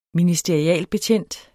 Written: ministerialbetjent

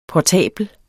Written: portabel

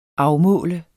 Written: afmålt

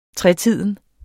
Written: tretiden

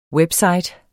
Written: WEBSITES

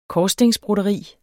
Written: korsstingsbroderi

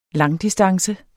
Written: langdistance

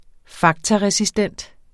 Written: faktaresistent

